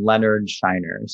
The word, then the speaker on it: Leonard Scheiner: And uh, I am online everywhere under the name Leonard Scheiner.